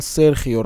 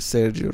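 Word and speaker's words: Sergio: همه دیگه میگن سرخیو راموس کسی نمیگه سرجیو راموس مثلا یا سرجیو رو خیلی کم میگن فقط گزارشگر انگلیسی میگن هم میگن سرخیو آره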